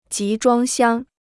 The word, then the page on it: 集装箱: jí zhuāng xiāng